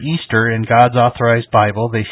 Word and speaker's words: Ishtar: Easter, as we know it, comes from the ancient pagan festival of Astarte, also known as Ishtar, pronounced Easter.